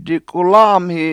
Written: dikulAAmhi

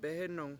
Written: behEnung

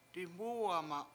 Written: diMUwema7

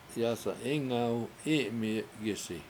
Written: yAsa7 Ingaw I7mi7i7gIsi

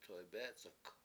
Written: toybEEc71k

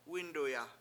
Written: wInduya

